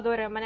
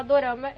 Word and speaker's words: dorama: Dorama veio da pronúncia japonesa de drama? – Sim, veio da pronúncia japonesa de drama. E também tem a ver com a pronúncia coreana, né? Porque às vezes eles falando drama também são um pouco como dorama.